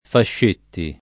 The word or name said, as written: Fascetti